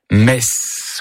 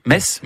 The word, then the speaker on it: Metz: Il s'agit bien sûr du TZ qui finit Metz ou Metz. – Oui. – Metz. Dis-moi comment tu prononces Metz, je te dirai qui tu es.